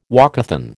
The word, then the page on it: walkathon: American English